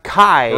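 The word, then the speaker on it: Chi: Keyblade with the Greek letter key, which is actually pronounced Kai.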